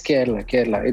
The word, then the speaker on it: Kerala: Yes, Kerala.